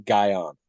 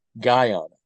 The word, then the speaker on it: Guyana: So I have zero interest in ever visiting the country of Guyana. I want to pronounce that very carefully. Not Ghana. I actually have some interest in visiting Ghana. Guyana, specifically.